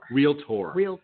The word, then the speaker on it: Realtor: Realtor. Got to say it properly.